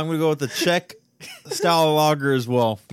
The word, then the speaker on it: Czech: Yeah, it's Czech, like checkmate.